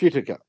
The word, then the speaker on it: Whittaker: Oh, I think you'll find it's pronounced Whittaker.